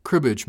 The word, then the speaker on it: cribbage: He called it a crib batch board. His brow furled. Crib something. Cribbage, Mr. Hill. Cribbage. I pronounced it distinctly for him.